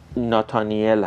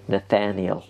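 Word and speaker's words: Nathaniel: ناتانیل هست ناتانیل خب در زبان انگلیسی این به این شکل تلفظ میشه آب دهنم گرد بدم ببخشید نتانیل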